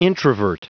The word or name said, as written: introvert